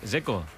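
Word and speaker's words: Džeko: Porque Dzeko. DZ, ¿cómo se pronuncia? – Dzeko. Bruno Dzeko. Dice que es Dzeko. – Como tirando a J Dzeko o algo así.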